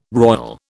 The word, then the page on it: royal: British English